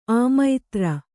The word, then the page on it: ಅಮಿತ್ರ: amitra